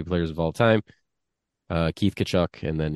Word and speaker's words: Tkachuk: The T is very silent. It's Tkachuk.